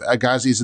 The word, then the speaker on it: Agassiz: Agassiz, right.